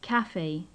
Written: cafe

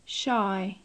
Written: shy

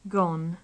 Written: gone

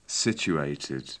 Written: situated

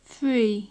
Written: free

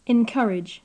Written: encourage

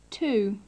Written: two

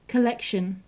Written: collection

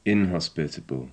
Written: inhospitable

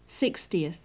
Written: sixtieth

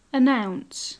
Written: announce

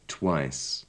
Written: twice

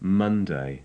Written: monday

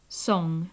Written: song

